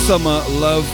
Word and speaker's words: SMRLVE: Before that, Sue McLaren teamed up with SMRLVE, which I have recently discovered is actually pronounced Summer Love.